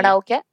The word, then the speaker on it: karaoke: How do you say it? How do I say it properly? – Karaoke. – Karaoke. – Yeah, right. – Okay, let me not say it like that, because it sounds like I'm taking a mic. Karaoke, I try and say.